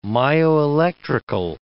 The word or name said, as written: myoelectrical